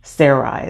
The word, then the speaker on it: Sarai: Sarai is how it's pronounced.